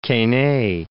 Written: Quesnay

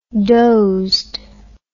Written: dozed

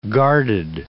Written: guarded

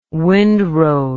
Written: windrode

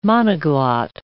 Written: monoglot

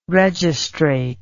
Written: registrate

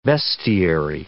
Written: vestiary

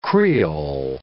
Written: creole

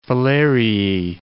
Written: filariae

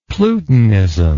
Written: plutonism

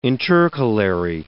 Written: intercalary

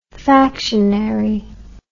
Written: factionary

